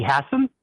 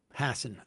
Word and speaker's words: Hassan: Let me ask you, how do you say the other senator's last name? – Maggie Hassan? – Hassan, okay. – Yeah.